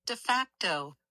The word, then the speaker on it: de facto: D-E space F-A-C-T-O. D-E space F-A-C-T-O. – De facto. – De facto. All right. It's an adverb, and it means in fact, in reality. Although his title was prime minister, he was de facto president of the country.